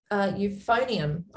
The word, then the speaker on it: euphonium: You phone in. That's not a bloody hotline, euphonium.